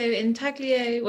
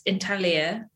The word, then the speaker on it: intaglio: Yeah, so intaglio, well, it's intaglia if you're Italian. I guess you don't pronounce the G.